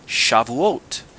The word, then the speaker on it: Shavuot: And as we're going to see, this festival, Shavuot, it's spelled S-H-A-V-U-O-T. And some people ask, how do you say that? It's Shavuot.